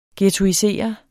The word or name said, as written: Ghetto